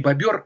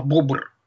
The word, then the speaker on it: бобр: Надо говорить правильно, не бобер, а бобр. Вот если вы хотите по-научному говорить, бобр, вот наш бобр – это Кастор Бибер, его латинское название.